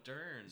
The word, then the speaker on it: Dern: Oh, Dern! – She had to step out before. – That's how you say it, Dern. I've only ever seen it in print.